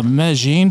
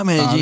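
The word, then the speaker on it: Medellín: And Colombia has a lot of manufacturing, and they have a Medellin, for example. Some people don't know how to pronounce that. They say Medellin or something, pronounced Medellin.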